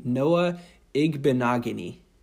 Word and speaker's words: Igbinoghene: I almost say that just because I like to show off that I know how to say the name. Noah Igbinoghene.